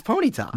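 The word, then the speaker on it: Ponyta: What is this wrong emphasis of the wrong syllable? – Do you think it's Ponita? – It's Ponita.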